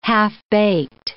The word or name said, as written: half-baked